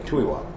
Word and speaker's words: Tuy Hoa: It's spelled T-U-Y-H-O-A, Thuy Hoa.